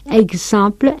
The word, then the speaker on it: exemple: Y también GZ. Exemple, exemple, examen.